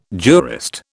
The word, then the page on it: jurist: British English